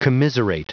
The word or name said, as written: commiserate